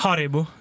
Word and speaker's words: Haribo: Garibo! – Garibo. Garibo. Ja. – Garibo. Sånn Haribo.